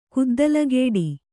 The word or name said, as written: ಕುದ್ದಲಗೇಡಿ